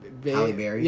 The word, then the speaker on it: Halle Berry: You know, Haley... Besides. Everyone wanted, like, would rather have Haley... – Halle Berry. – Yeah, Halle Berry.